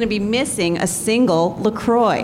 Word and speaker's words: La Croix: I brought my favorite beverage, grapefruit flavored La Croix, sometimes pronounced by uncultured people La Croix.